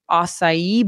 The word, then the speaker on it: acai: And they will offer acai bowls, wraps, and rice bowls.